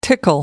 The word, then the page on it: tickle: American English